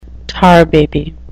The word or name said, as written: tar baby